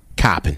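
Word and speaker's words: Coppin: Now, do I pronounce that Koppen or? – Koppen. – Koppen.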